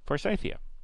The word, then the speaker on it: Forsythia: It's named after an English horticulturist who brought the first plant from East Asia, and his name was John Forsyth, thus the pronunciation Forsythia.